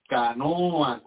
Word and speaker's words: canoa: y ellos le llaman canoa, con la última sílaba lengua, luenga, larga, esa canoa.